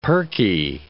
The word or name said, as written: perky